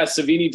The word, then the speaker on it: Savini: Apparently, they even Tom Savini. Is it? Am I saying that right? – Yeah, Savini designed that like Hell Jason, and really Kane Hodder did all the motion capture for it.